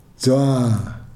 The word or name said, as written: jwa